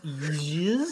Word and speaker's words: Jizz: Now, is that pronounced Jizz? – Well, I'd like to tell you, it's spelled J-I-Z-Z, so I don't know how else you'd pronounce it.